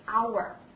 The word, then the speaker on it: hour: 那么我看到本单元的最后一个单词 hour，也是同样的注意发音的。饱满的感觉，而且要卷舌啊，在美音当中 hour， OK，好，首先呢表示小时，还可以表示就是比如说一点、两点、三点这种正点啊，或者是时刻、时间的意思。